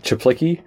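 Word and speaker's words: Czaplicki: I mean, Evan, I can never pronounce his last name. – It's so he pronounces it Chaplicky.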